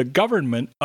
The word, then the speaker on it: government: Our next word for pronunciation tips is government. Government. So we have three syllables. The first is a schwa sound. The second one is a short e blended with r, er. And the last syllable is a short e blended with n, n.